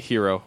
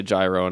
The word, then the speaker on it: gyro: Is that how you say it, or do you say it hero, or do you say gyro? – Euro. – That's what I say, too. I hear people say gyro, and it makes me think they're heathens.